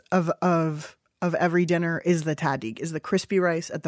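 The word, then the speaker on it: tahdig: How do you pronounce it, Samin? – Yeah, tadig. You got it. You're doing a good job.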